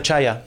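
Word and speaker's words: Czaja: De secretario general y de portavoz este Mario Cacha, ¿no? – Eh, Mario Chaya. Chaya, digo, Cacha, mira, mira, qué pronunciación impresionante. Cacha a Chaya.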